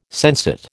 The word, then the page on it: sensate: American English